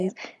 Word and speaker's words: Charlize: Charlize, and Margot Robbie, mm-hmm.